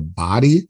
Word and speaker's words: Baade: but now it's Cynthia Baade.